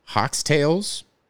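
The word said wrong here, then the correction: Heaux Tales: H-E-A-U-X, Hotails.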